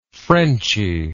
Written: frenchiness

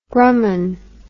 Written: grumman

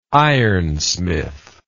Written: ironsmith

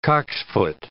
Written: cocksfoot

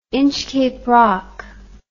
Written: Inchcape Rock

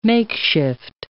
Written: makeshift

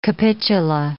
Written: capitula

